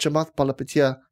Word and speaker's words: Chamath Palihapitiya: That's what Chamath Palihapitiya, I can pronounce his name right. – Well done.